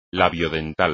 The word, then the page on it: labiodental: Spanish